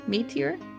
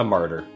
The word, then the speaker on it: martyr: Some saw Ginger as a martyr. How do you say that? Meteor? – Yeah, a martyr.